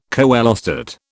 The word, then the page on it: coelostat: British English